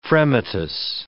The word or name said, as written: fremitus